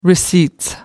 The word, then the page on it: receipts: British English